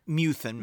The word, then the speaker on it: Methuen: Shirley runs a funeral parlor with her husband, Kevin, in, I hope I'm saying this correctly, Methuen, Massachusetts? – Methuen, Massachusetts.